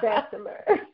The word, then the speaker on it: Bessemer: I grew up in what other people call Bessemer, but I say it's Bessemer.